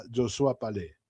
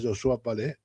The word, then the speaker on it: Josué Palais: Si buscas ahí en el YouTube, para que, si no sabes francés, pones ahí Josías Palais y te va a dar la pronunciación correcta, Josua Palais. Así, habría que preguntarle a él cómo quiere que lo nombren, no que lo llamen, pero bueno, la pronunciación correcta es Josua Palais en francés.